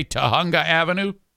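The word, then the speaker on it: Tujunga: The J is a soft J, Tahunga Avenue in L.A.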